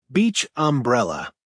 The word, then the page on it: beach umbrella: American English